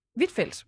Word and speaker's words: Huitfeldt: Jeg ved ikke, om jeg udtalte det. – Ja, Huitfeldt. – Hvidtfeldt? – Okay.